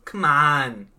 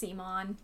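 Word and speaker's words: CMON: It's pronounced Seamon. – Oh, it's Come On. – Seamon. – Come on. – They get a little irritated when you don't pronounce their name right. – Come on.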